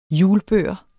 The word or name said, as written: hjulbør